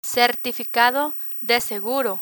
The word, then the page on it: certificado de seguro: spanish